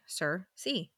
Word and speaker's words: Sursea: This was S-U-R-S-E-A. Cersei. Easy peasy.